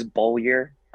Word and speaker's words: Beaulieu: Very, very close. It's the English translation is Beaulieu.